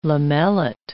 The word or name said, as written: lamellate